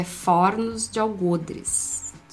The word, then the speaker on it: Fornos de Algodres: E eu acho que a pronúncia certa disso é Fornos de Algodres.